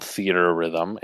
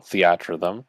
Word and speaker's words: Theatrhythm: It is not called Theater Rhythm. It is called Theatrhythm.